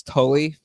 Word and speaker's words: Tully: Lewis Talley, are you just mispronouncing Lewis Tully from Ghostbusters? – That might have been Tully.